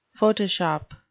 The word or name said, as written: photoshop